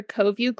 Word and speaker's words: CoViu: I work for CoView Global, and it is a word that is spelled C-O-V-I-U, but it's said as CoView, like two people viewing, always